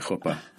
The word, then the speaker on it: chuppah: My niece knit her own chuppah. – Which is nice. – Am I pronouncing that right? – Yeah, yeah. You can go as hard as you want in the chuh.